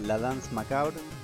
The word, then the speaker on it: La Danse Macabre: Esto que estábamos escuchando es el tema La Danse Macabre, no sé bien cómo se pronuncia. – La danse macabre.